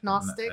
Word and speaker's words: Gnostic: In some Gnostic, am I saying that right? G-N-O-S-T-I-C? – I think Gnostic. Gnostic. – I think it's Gnostic.